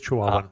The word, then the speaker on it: Chihuahuan: It might be Chihuahua or Chihuahuan. I'm okay. – not sure. – Got it. It is Chihuahuan. You are correct.